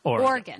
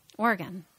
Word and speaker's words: Oregon: Oregon. – Oregon. – Oregon. Oregon. – Just two syllables. – Two? – Two syllables. – It's not Oregon. – Oregon. No. Oregon. – Oregon. Like a body organ?